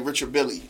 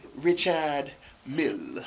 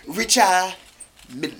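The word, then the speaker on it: Richard Mille: You know how to say Richard Mill? Richard, you know, rappers say Richard Billy or some shit like that. Mm-hmm. It's Richard Mill. Richard Mill. Like, that's how you say it, bro. You don't say the D. Richard Mill.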